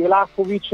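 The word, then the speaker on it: Vlahović: No, io non sarei per la cessione di Vlahovic, te lo pronuncio come so che andrebbe pronunciato, non per fare, perché ormai ho preso l'abitudine a pronunciarlo Vlahovic e quindi tutti mi criticano, ma sarebbe la pronuncia corretta.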